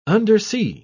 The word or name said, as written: undersea